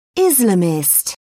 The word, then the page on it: islamist: British English